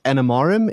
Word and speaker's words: Animarem: And that, so this led you to starting a consulting business, a services business, and you've called that Animarum. Is that right? Have I pronounced that right? – That's correct, yes.